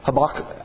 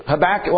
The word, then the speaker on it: Habakkuk: Or some may say his name is said Habakkuk. You know, if you want to do a little bit more German guttural or Hebrew sound. Habakkuk. Most likely Habakkuk.